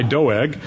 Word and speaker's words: Doeg: The psalm begins with asking why Doeg, and I draw your attention to the E, not a U. This is. I have to pronounce it distinctly through the whole sermon.